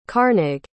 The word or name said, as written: carnig